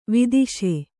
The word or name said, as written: ವಧಿಸು